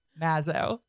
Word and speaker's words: Mazzio: Mazzio. I mean, I did a little reel about it because I was like, it really is just as easy. as easy as it looks. It's a short A. Mazzo.